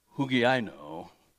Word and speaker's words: hugiaino: The Greek word for sound is the word hugiaino, is the way it's pronounced. The Y is a U, but anyway, it's Greek.